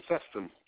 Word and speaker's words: Seston: Uh, Tim Seston. Like the town of Weston.